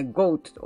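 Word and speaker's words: GOAT: 発音はそのヤギを意味するね、ゴートと同じなので、でも意味は全く別物なんですね。で、チャット GPT に大谷選手もゴートだよねって聞いたら、はい、大谷翔平選手もまさにザ・ゴートと呼ばれるにふさわしい選手です。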